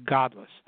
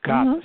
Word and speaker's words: Godlis: You want to spell it G-O-D, like God, and then L-I-S. So it's Godlis.